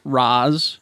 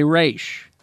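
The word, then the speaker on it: Ra's: Some characters pronounce Raz, while others say Raish. If you are part of the League of Assassins, you say Raish. – Ooh. – As a sign of respect, the proper way to pronounce it. If you are outside the League and you're not a rogue, as a sign of disrespect, you say Raz.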